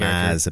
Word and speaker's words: Mads: Mads, apparently. – Mads? – Silent D.